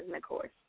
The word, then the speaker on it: Nicois: I mean, technically they're not wrong because that is how you say it in French, but my pronunciation is Nicois.